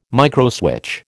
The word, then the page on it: microswitch: American English